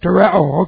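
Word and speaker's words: Tereo (τηρέω): Okay, the word is tereo. T-E-R-E, long O. Tereo.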